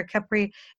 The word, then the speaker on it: Capri: So she's like, is it Capri or Capri? It's Capri. It's just like the island in Italy, Capri.